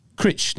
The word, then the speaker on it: Cruit: It's spelt C-R-U-I-T, but it's pronounced Critch.